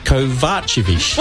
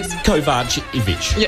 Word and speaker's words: Kovacevic: Lisa Kovacevic. – Well, oh, so close. That was really close. – Is it the ish or an itch? No, okay. Lisa Kovacevic.